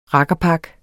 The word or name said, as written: rakkerpak